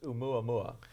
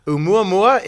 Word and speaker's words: Oumuamua: It's very simple to pronounce, actually. It's just Oumuamua. It's a Hawaiian word. And since I love Hawaii, Hawaiian just comes naturally.